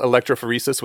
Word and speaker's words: electrophoresis: You had this interesting thing, lab work protocols, where you gave a bad example is a protocol of gel electrophoresis.